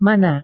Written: mana

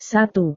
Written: satu

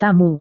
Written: tamu

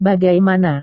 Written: bagaimana

